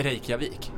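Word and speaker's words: Reykjavík: Kan du uttala det som de uttalar det? – Reykjavik. – Ja, så viktigt.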